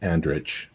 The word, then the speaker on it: Andric: Andric, A-N-D-R-I-C. Actually it's probably pronounced Andrich.